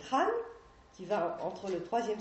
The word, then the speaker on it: Han: Donc les principaux noms que je vous citerai, c'est la dynastie des Han, H se prononce avec un R, dynastie des Han.